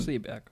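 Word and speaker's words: Seberg: Und mit The Burg meine ich Seaburg. – So spricht Deutsch man. Seeberg.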